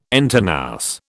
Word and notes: entre nous: British English

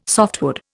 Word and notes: softwood: Indian

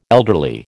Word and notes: elderly: American English

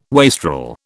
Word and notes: wastrel: British English

